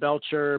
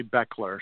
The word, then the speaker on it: Bechler: And then my last name looks like Bechler. Everybody pronounces it Belcher, but it's really Beckler.